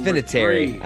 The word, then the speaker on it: Vinatieri: It's Vinatieri. I know it's pronounced Vinatieri, but like, get over yourself. All right, all it's right, Vinatieri. It sounds like a cheap wine.